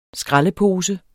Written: skraldepose

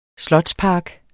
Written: slotspark

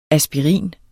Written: Aspirinen

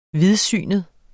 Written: vidsynet

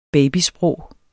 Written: babysprog

